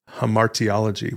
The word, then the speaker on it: hamartiology: And then Ross, connected to it, and now we're starting to get into some of the difficult to pronounce words, we have this study called hamartiology. What's that? – Well, good job. You pronounced that quite correctly.